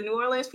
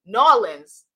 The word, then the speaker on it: New Orleans: Let me get it right, because the New Orleans people come after me. New Orleans. Did I say that right? No, I say it like a Yankee.